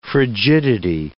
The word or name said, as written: frigidity